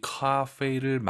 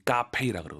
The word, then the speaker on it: 咖啡: 저는 왜 커피를 넣냐면요, 발음이 어려워서. 많은 분들이 카페이라 그러거든요.